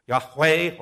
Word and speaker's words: Yahweh: A more correct rendering would be YHWH or YHVH, and pronounced as Yahweh or Yahweh.